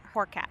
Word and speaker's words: Hurkacz: And a new player just recently, Hubert Hurkacz. – Yes. – Did I say it correctly? – You did.